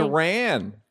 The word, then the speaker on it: Iran: Iran. – It just, do you know anybody named Ron? – Yeah. – Cousin named Ron, anybody named Ron, R-O-N, and just put a big fucking E, like email.